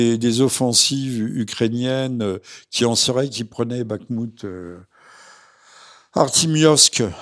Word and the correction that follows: Artyomovsk: je prononce mal, Artyomovsk, euh, selon le nom russe, euh, vous savez, euh, Kharkiv, c'est Kharkov, etc.